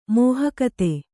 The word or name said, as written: ಮೋಹಕತೆ